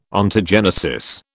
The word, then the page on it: Ontogenesis: British English